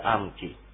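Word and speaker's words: アㇺキㇼ: カタカナで書くとアムキリになってますが、こうムとリをちっちゃく書きます。これアイヌ語独自の発音ですので、こう息を止めるような感じでアムキという発音になります。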